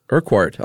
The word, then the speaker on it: Urquhart: How do you pronounce that? Urquhart? – I was going with Urquhart. – Oh, Urquhart. I like that. – I do too. – Silent H. Yeah. – But also the qua.